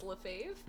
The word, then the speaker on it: Lefebvre: Yeah, on my birthday, I expressed to Sarah that my last name was pronounced Lefebvre.